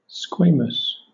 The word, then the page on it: squamous: British English